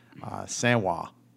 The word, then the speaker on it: Samhain: and the word is pronounced Samhwa. Samhain. Something like that, yeah.